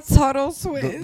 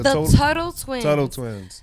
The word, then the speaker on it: Tuttle Twins: There's something else that you do, and we were talking about it, the Tittle Twins. – Oh yes, the Tuttle Twins. – How do you pronounce it? – The Tuttle Twins.